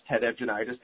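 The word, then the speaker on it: Ted Evgeniadis: His last name is spelled E-V-G-E-N-I-A-D-I-S, Ted Evgenitis, the Lower Susquehanna Riverkeeper up in Pennsylvania.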